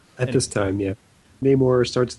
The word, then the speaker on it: Namor: Do you pronounce it Namor? See, I always pronounce it Namor. – Oh, I have no idea. I've always pronounced it Namor. Namor. – That's okay. – Namor sounds gay more. So I'm going with Namor.